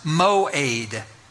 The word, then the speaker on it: moed: The Hebrew word, which is the language of the Old Testament, the Hebrew word that's translated appointed time is a Hebrew word that's pronounced moed, moed.